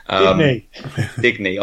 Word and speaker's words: Digne: Digne, I think, is the actual pronunciation, which I probably didn't quite get right when I first kicked off. – Digne. – I like Digne.